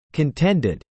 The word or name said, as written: contended